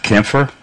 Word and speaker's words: Camphire: Yeah, because if our listeners were to actually look at this word in writing, it would look like an old English version of campfire, C-A-M-P-H-I-R-E. But it's obviously, as our guest has already demonstrated, it's not pronounced that way.